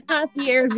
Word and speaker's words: concierge: you know, you got the concierge good. It's there. You got it.